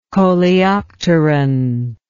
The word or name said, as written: coleopteron